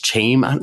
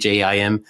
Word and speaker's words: Chaim: and it's Chaim, his pronunciation of his name was there, Chaim Gingold. That's Chaim.